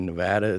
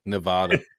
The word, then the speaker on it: Nevada: You said Nevada, right? A lot of people say Nevada, and then the locals here hate that. So props to you for saying it right.